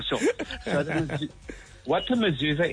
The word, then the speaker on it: mezuzah: So what I want to tell you is the mezuzah, you pronounce it correctly.